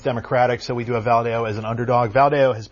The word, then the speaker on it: Valadao: currently represented by Republican David Valadao, I think I'm pronouncing that wrong. – Valadao, thank you, and being challenged by Democrat Randy Villegas.